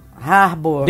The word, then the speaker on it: David Harbour: É isso a pronúncia? – Harbour. – David é. Harbour.